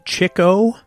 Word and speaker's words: Chico: Speaking of chickens, the proper pronunciation, Leonard Marx's nickname, is indeed Chico. According to Groucho in a later interview, his brother was a big fan of the ladies, referred to as chickens back then, later shortened to chicks, so they called him Chico.